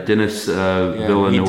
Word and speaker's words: Denis Villeneuve: Yeah, Denis Villeneuve.